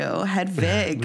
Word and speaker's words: Hedwig: You got to say it like the German girls do. Hedwig.